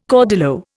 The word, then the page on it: caudillo: Indian